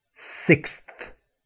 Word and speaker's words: sixth: And one of the most difficult words to pronounce for anybody, whether they're a native speaker or not, is the word sixth. Sixth. Yeah?